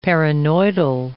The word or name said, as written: paranoidal